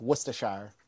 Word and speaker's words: Worcestershire: Okay, as a person who grew up in Worcester, Massachusetts, it's Worcestershire. You can say Worcestershire. But it's Worcestershire because it's based off of like British.